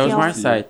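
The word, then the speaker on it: Omar Sy: Exatamente, eu não sei se é assim que se É Osmar Sai, tá? A gente abrasileirou o nome dele.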